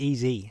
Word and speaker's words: Eze: And just finally, a young lad at QPR, believe I've got his pronunciation right, Eberechi Eze. – I think it's Eze. – Is it Eze? – I just asked Matt. – Eze. – Outside. – Eze. That's why, that's an Eze.